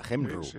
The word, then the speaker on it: Cymru: Dentro de un tiempo, País de Gales se va a llamar Cymru, que se pronuncia Hemru, porque lo van a poner nombre en galés.